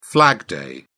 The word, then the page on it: flag day: British English